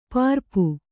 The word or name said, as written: ಪರಪು